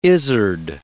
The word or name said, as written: izzard